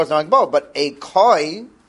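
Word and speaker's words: Koi (כוי): I'll say Kvi and everyone's going to correct me. No, that's not how you say it. You say Koi.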